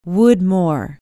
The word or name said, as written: woodmohr